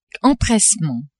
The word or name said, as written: empressement